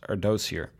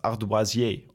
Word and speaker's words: ardoisier: En die zei, je noemde iemand die een rectificatie stuurde met een Twitter-handle, hoe ik toen uitsprak, was Ardoziër. Als je het zo schrijft, spreek je het uit als Ardoisier op zijn Frans.